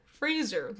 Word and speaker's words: Fraser: Fraser, like Razor. And he's like, people have been saying it wrong my whole career,